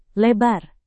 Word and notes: lebar: indonesian

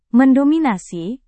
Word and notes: mendominasi: Indonesian

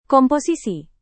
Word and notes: komposisi: Indonesian

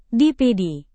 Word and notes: dpd: Indonesian

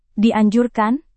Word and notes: dianjurkan: Indonesian

